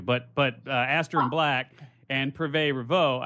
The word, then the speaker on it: Privé Revaux: Privé Revaux.